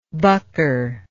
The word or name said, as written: bucker